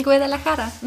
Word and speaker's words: Guadalajara: Guadalajara? – Ja. – Wirklich? – Guadalajara. – Guadalajara.